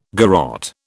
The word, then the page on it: garotte: British English